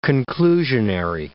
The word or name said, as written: conclusionary